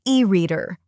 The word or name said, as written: e-reader